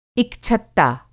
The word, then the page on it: इक-छत्ता: Hindi